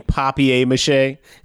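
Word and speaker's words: papier-mâché: He just went up, dude, like he was made of paper mache. uh, you mean papier mache? – yeah, sorry, I mispronounced it.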